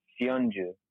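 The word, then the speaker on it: Söyüncü: You're playing a 3-5-2 with Ederson in goal, Michael Keane, Robertson, Chaglar Sonuk. Pronounce it. What? – Sionju. – Sionju on Leicester? He's Turkish, right? – Yeah. I found out how to pronounce it. – Oh, nice. – The C is a G.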